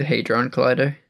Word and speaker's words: Hadron Collider: We haven't even gotten to the the Hadron Collider, or am I saying that right? – The Hadron Collider.